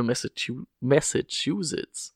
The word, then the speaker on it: Massachusetts: Äh, in zwölf Matches, ne, für Massachusetts.